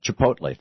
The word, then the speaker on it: Chipotle: And I went into and I walked into, I think it's pronounced Chipotle or however you say it. This Chipotle, thank you, Jacob.